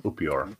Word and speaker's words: Upior: U-P-I-O-R. Upior. – Okay. So you're going to be actually like still in Upior is the correct pronunciation. Sorry.